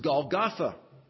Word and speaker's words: Golgotha: In Hebrew, it's pronounced Golgotha.